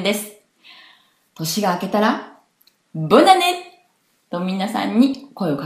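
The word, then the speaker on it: Bonne année: こちらは女性名詞ですので、前につくボンが、ブンヌに変わり、ブンヌアネ、ブンヌアネという言葉なのですが、こちら一緒に早く発音すると、ボナネ、ボナネ、ボナネという発音をします。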